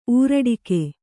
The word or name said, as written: ಊರಡಿಕೆ